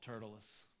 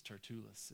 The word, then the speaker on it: Tertullus: We're introduced here initially to an orator named Tertullus, who I want to call Tertullus, but I guess it's Tertullus. It should be pronounced very slowly, and my southern wants to make it pronounced really fast.